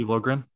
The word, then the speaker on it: Liljegren: Is Timothy Liljegren? – Yeah, Timothy Liljegren.